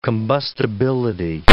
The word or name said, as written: combustibility